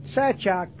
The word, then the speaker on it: Sachak: It was good to hear my name pronounced correctly. Sachak, Brother Sachak. How do you like that?